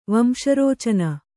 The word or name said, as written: ವಂಶರೋಚನ